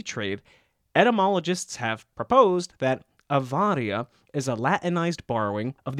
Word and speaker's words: avaria: You'll notice that I'm pronouncing the Latinized form of awar with a V sound, avaria. Now, that word, avaria, is spelled A-V-A-R-I-A, but in classical Latin phonology, which is the Latin phonology most frequently taught and learned, consonantal V is pronounced with a W sound, akin to the sound of English W.